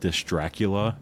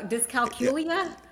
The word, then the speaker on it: dyscalculia: wants to say like dysdracula, but it's the math one. – Yes. – I don't know. Dyscalculia. – Yes. That's how you say it.